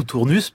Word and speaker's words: Tournus: Alors à Tournus, Tournus ou Tournus, puisqu'on est vraiment à la frontière du nord et du sud de la France, donc on accepte bien évidemment les deux prononciations.